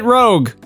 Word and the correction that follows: Rouge: I don't care Rouge. If it's spelled right. – It's Rouge. – It's a name. You have to read it properly.